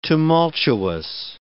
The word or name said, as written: tumultuous